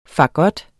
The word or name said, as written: fagot